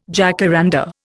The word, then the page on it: jacaranda: Indian